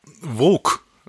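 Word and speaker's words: Voq: Also ich höre da nicht Wuck, ich höre da Wok quasi, also mit OU. Und ich finde, das ist schon relativ nah an der englischen Aussprache auch.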